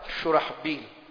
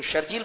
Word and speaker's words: شرحبیل: یہ نام ہوتا ہے شرحبیل. تو شرجیل پڑھتے ہیں، با کے نقطے کو پیچھے گھسیٹ لیتے ہیں، اس لیے کام پھر ایسے کر لیتے ہیں. لیکن جو شرحبیل ہوتے ہیں، تلفظ ٹھیک ہوتا ہے، پھر ان کے کام ایسے نہیں ہوتے.